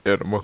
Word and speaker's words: Anamma: A-N-A. – No, I hope it's not Anima. – How would you pronounce that? – Where you at? – A-N-A-M-M-A. – Anima. Yeah. Okay.